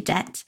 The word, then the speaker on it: debt: Okay, now hopefully that helps you understand the different ways in which you can manage or consolidate your debt to become debt-free. Now, obvious, I think I keep saying debt-free. It's not debt. It's a silent B. It's not a P.